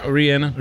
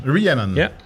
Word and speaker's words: Rhiannon: Nee, hoe zeg ik dat goed? – Ja, Rhiannon. – Rhiannon.